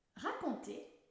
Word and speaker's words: raconter: Raconter veut dire to tell. Il est important de bien prononcer le A dans la première syllabe. Il n'y a pas de R après le T, donc on prononce ra con Il s'utilise surtout dans l'expression raconter une histoire.